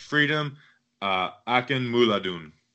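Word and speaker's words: Akinmoladun: I went and looked up some stuff, and it's pronounced Freedom Akinmuladun. Akinmula, like money, dun. I even have like all the O's in the middle.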